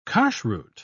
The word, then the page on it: kashruth: British English